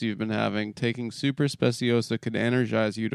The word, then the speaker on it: Speciosa: Speciosa, pronounced Speciosa, and Kratom, pronounced Kratom.